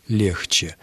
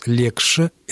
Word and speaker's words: легче: Юрий Григорьевич, никакой ошибки в этом произношении нет, за исключением слова «легче». «Легче» нужно произносить именно так, как я сейчас произнес. «Легше» – это орфоэпическая ошибка.